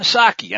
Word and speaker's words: Psaki: One of those who obviously hasn't is the administration's chief spokeslier, Jen Psaki. And I know you've heard this, but it bears repeating. Remember, folks, the P is silent, like it is in resident.